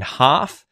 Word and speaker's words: half: There are words where L appears in the spelling of words like walk, talk, folk, and half. However, the L is not pronounced, okay?